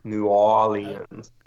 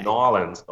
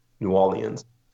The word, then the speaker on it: New Orleans: So you sort of skip over the R, New Orleans. Some people say Okay. – Nolens. Oh yeah, Nolens. It's a little bit, there's a little bit, few more syllables. New Orleans.